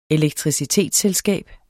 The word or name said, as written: elektricitetsselskab